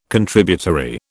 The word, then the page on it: Contributory: British English